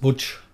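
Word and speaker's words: Łódź: Auf Polnisch heißt das jetzt Wutsch. – Ja, aha. – Ist das ausgesprochen? – Das ist die polnische Aussprache, ja.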